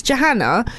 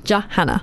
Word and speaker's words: Johanna: So it's Johanna, like J-A Hannah, Johanna. – Yeah. – But people read it and they're like, Johanna? Johan? Are you Swedish?